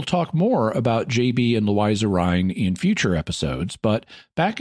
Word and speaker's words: Louisa Rhine: With a book by parapsychologist Louisa Rhine, and yes, it is pronounced Louisa Rhine, not Louisa.